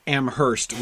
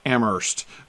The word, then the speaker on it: Amherst: I keep saying Amherst, and I know in Massachusetts they don't pronounce the H. I grew up in Amherst with an H, New Hampshire. So I always pronounce it that way, but it's actually Amherst when you're down in Massachusetts.